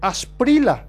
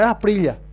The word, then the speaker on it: Asprila: Asprila, creo que es el apellido del arquero, ¿verdad? No es Asprilla. O Asprila nomás se lee, se pronuncia. No, pero es con L.